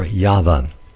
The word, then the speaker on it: Yahweh: It is written YHVH, Yod-Heh-Vav-Heh, or the Tetragrammaton, the letters, the Hebrew letters, and it's pronounced either Yod-Heh-Vav-Heh, you could pronounce it that way, or Yahweh.